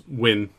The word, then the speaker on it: Nguyen: Yeah, I think it's pronounced Win. I think that's correct.